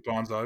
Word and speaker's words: Banzai: It's actually Banzai, but that's all right. I'll let that one go. – What did I say? – Banzai.